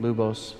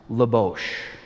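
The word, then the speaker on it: Luboš: So Danon Lubos Veverka. But Lubos is properly pronounced Lubosh.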